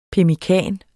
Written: pemmikan

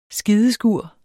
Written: skideskur

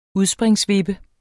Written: udspringsvippe

